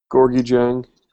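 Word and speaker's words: Gorgui Dieng: Gorgui Jang.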